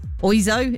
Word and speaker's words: Oizo: Mr. Ozio, I never really knew how to say it. Oizo? It's not Ozio, is it? It's O-I-Z-O.